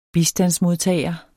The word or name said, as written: bistandsmodtager